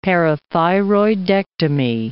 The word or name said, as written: parathyroidectomy